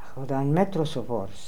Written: Akhaldanmetrosovors

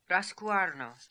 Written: Rascooarno

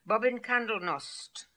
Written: Bobbinkandelnost

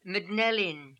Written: Mdnel In

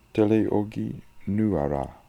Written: teleoghinooara

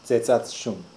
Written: dzedzatshoon

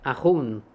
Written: Ahoon

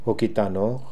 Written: Okidanokh

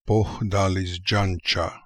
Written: Pokhdalissdjancha

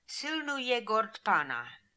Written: Silnooyegordpana

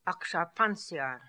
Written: Aksharpanziar

